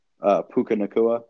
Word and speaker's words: Puka Nacua: Puka Nacua.